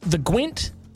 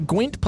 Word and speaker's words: Gwent: Well, a story just came across my radar of the Gwent police. – Mm-hmm. – It's pronounced Gwent, isn't it? Not Gwen or something. It's Gwent. – G-W-E-N-T.